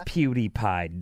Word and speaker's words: PewDiePie: It's PewDiePie, dummy.